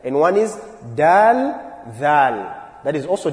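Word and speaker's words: dhal (ذال): Dhal. The one which is after dal. So the easy way to know the pronunciation of this letter, dhal, is you must make sure the tongue comes out.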